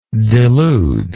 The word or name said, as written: deludingly